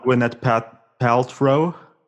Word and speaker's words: Gwyneth Paltrow: And we also have Gwyneth Paltrow. Is that how you pronounce her name? – Yeah.